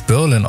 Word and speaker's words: Berlin: Die Gruppe Berlin oder Englisch Berlin ausgesprochen, aber die kommt nicht aus Berlin, sondern aus Los Angeles.